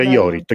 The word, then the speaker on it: Jorit: Si pronuncia Jorit.